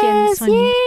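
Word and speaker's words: 甜酸鱼: 天酸鱼. The intonation's correct? – Correct.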